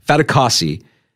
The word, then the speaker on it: Fatukasi: And we're talking about defensive tackle Foley Fatukowski. I'm sorry, I said his name wrong. Fatukasi is with the Bills today.